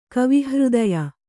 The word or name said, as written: ಕವಿಹೃದಯ